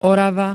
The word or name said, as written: Orava